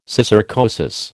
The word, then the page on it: syssarcosis: American English